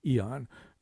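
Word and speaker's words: Aeon: We've now decided, I think officially, we're going to say Eon, although we've never known how to pronounce that magazine.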